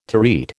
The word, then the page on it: terete: American English